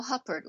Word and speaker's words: Huppert: Mathieu Amalric is Mr. Fox, and Isabelle Huppert, or actually Huppert, but the English often pronounce it Huppert.